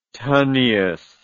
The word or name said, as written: tonnies